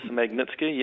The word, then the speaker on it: Magnitsky: But the point I've got here is, and correct if my pronunciation is, a Magnitsky sanctions. Could you? – Oh, yes, Magnitsky, yep. Yeah. Oh, good, I got it right.